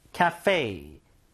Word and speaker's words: cafe: 카페가 아니라 영어 발음은 cafe, cafe, ca에 가깝고 fe, fe.